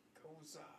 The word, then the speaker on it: Gose: Goza. – Is how it's supposed to be pronounced.